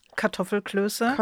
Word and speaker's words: Kartoffelklöße: Kartoffelklöße. – Kartoffelklöße.